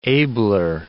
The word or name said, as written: abler